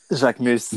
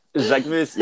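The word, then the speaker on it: Jacquemus: Because I don't know how to say it right. – Jacques Mus. – Yes. – That's it. – Jacques Mus, yeah.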